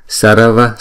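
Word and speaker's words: sarva: Chữ này thực sự là hai âm chứ không phải ba âm, bởi vì chữ R này không đọc là R rõ ràng, mà đọc chỉ đánh lưỡi lên thôi, Sarava, Sarava.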